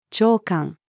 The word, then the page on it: 朝刊: female